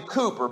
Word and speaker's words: Cowper: And one of my favorite hymn writers, it looks like his name would be pronounced William Cowper. His name is William Cooper, but it's spelled C O W P E R.